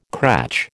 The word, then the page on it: cratch: American English